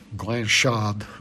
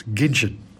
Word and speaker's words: Guinchard: G-U-I-N-C-H-A-R-D, which I'm reliably informed is not pronounced Glanchard or anything like that. It's Ginchard, Rufus Ginchard.